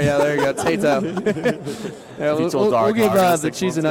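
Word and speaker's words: Tayto: Tato. I'm saying that right, Tato? – Yeah.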